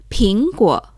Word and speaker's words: 苹果: En chino es ping guo.